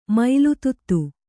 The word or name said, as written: ಮೊಲೆದುದಿ